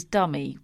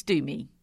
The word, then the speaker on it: Dumi: D-U-M-I. – Do. – You do. Thank you. Well, the alternative is Dummy, which it's not Dummy, is it? It's Doomy. Doomy. Doomy the Dachshund, owned by Christiane. Doomy